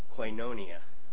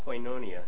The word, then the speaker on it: koinonia: and that is the word koinonia. Koinonia, and it's spelled K-O-I-N-O-N-I-A.